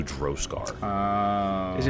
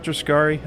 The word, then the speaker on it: Droskar: You would know that, like, the legend is that the Haringar people have these innate abilities because of their faith to Droskar. – Is it Droskar, not Droskari? Am I saying it wrong? – Yeah, Droskar. Yeah. If you are a worshiper, you are a Droskari.